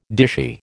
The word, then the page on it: dishy: American English